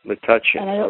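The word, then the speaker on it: Metuchen: And also helping me to pronounce the name of your where you live, because I never could do it. Matuchin, okay.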